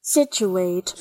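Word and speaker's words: Scituate: I beg your pardon, John, but Joe, I have your pronunciation of the town you requested. – All right. – Situate, Massachusetts. – You are correct, Joe.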